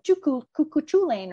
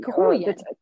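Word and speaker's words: Cú Chulainn: Cuchulainn, that's the pronouncing of his name, because I always wanted to call it Cuchulainn or something like that, but Cuchulainn.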